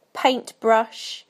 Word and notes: paintbrush: British English

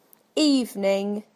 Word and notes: evening: British English

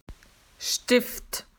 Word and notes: pencil: Australian English

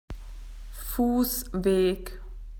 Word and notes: footpath: Australian English

